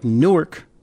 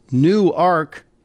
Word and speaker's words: Newark: I know to people in New Jersey and New York, it looks like Newark, but down here in Delaware, it's pronounced Newark.